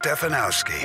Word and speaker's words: Stefanowski: Stefanowski? Is that how you say it? – Stefanowski. – Stefanowski? All right, let's find out. – Stefanowski. – Ah, you nailed it. Good job.